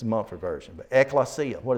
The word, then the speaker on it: ekklesia: That ek is actually more like, if we were, well, the way it's spelled, if you will, dropping it into English letters, it's E-K-K-L-E-S-I-A. But it's more similar to that red sign there. Exit. Out of.